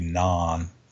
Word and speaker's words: Nantes: Matt Miazga is the other high-profile American in France at Nantes. Is that how you say it? Nantes? – That's how I'm saying it, yeah. I mean, you could add a little, like, more throatiness to it. It could be non.